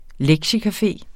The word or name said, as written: LEKTIECAFE